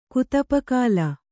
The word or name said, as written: ಕುತುಪಕಾಲ